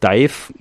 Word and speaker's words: DIIV: Die Band nannte sich 2011 ursprünglich Dive, also geschrieben D-I-V-E. Eine direkte Hommage an den gleichnamigen Nirvana-Song. Um aber Überschneidung mit dem belgischen Industrial-Projekt Dive, also genauso geschrieben, zu vermeiden, änderten sie sich ihre Schreibweise kurzerhand in Dive, D-I-I-V. Ausgesprochen bleibt es weiter Dive.